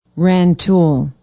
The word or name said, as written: rantoul